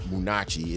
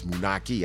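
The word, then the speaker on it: Moonachie: Munaki. That's the way you say it. It's not Munachi, it's Munaki.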